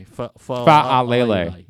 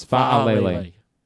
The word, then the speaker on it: Fa'alele: Fa'alele. Yes. Fa'alele. Fa'alele. Four syllables.